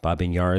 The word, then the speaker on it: Babi Yar: The memorial at the Babi Yar site, Babi Yar is how they say it in Ukraine,